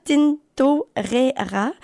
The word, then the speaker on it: Tintorera: C'est Garnacha Tintorero. Pardon. Garnacha Tintorera.